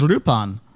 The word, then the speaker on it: Lupin: With an R, because then they could say, look, he's not, you know, L-U-P-I-N, he's Rupan. And so they could just say, like, okay, we'll approximate the Japanese pronunciation.